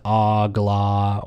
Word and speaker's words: AGLA: Agala. Some people pronounce it agla or agala.